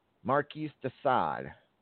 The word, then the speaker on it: Marquis de Sade: Marquis de Sade.